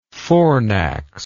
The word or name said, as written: fornax